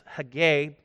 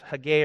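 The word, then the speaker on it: Hegai: or at times pronounced Haggai, it's pronounced both ways, Haggai or Haggai, that Esther also was taken to the king's palace, into the care of Haggai, the custodian of the women, verse 9.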